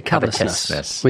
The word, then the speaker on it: covetousness: Covetousness. – There's a ch, but you don't do that in New Zealand. – We say covetousness.